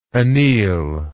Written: anele

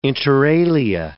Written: inter alia

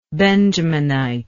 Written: Benjaminite

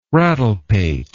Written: rattlepate

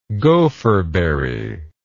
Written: gopherberry

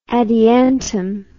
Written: adiantum

